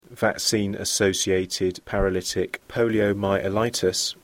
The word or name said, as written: vaccine associated paralytic poliomyelitis